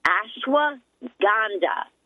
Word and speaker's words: ashwagandha: A-S-H, like ash, W-A-G, like wag, A-N, like Nancy, D-H-A, ashwagandha, okay?